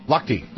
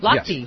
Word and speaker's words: Lochte: Ryan Lochte? – Lochte. – Lochte.